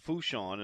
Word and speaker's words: Fourchon: It's at Fouchon, and if you want more information on the rodeo, you can just simply Google it, Fouchon, and that's spelled like Fourchon, F-O-U-R-C-H-O-N, Tarpon.